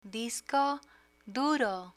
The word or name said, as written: disco duro